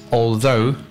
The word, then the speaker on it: although: And the other one would be although, okay? Although is the correct pronunciation. Although some people tend to say also, also.